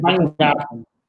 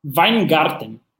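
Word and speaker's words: Wajngarten: É, no caso do, do Marcelo, uh, Marcelo não, do Fábio, eu não sei pronunciar o segundo nome dele. – Weingarten. – Como? – Weingarten.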